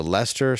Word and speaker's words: Leicester: you can catch me at the Leicester Square Theater in the UK oh. – next March, the March 23rd. Tickets are on sale now. That's the Leicester Square Theater, March 23rd, 2024.